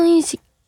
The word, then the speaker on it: 隕石: 火星の隕石、これ隕石って読みますよね。